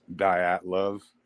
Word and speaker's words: Dyatlov: Unfortunately, uh, that's the way that, uh, that I've learned to just to pronounce it is kind of like diet would, diet love, like that.